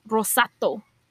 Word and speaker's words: rosato: Italian is rosato.